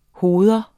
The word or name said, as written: hoved